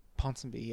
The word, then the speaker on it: Ponsonby: This is in, how do you say, Ponsonby? – Ponsonby, yeah.